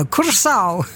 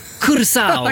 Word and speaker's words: Curaçao: Då vet ju du hur man uttalar landets namn. – Ja, man säger Curaçao. – Curaçao. – Ja, Curaçao.